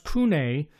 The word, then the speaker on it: CVNE: Now, it's spelled CVNE. It's all capitals, but it's actually pronounced Cune Monopole White Rioja, and it's 100%.